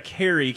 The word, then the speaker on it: Kari: It's Carrie, K-A-R-I. – I bet people say Carrie. – They must say Carrie a lot.